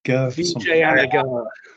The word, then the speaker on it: Vijayanagar: Vijayanagar. – Vijayanagar.